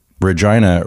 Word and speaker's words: Regina: The gag, Regina rhymes with vagina.